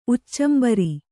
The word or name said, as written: ಉಚ್ಚಂಬರಿ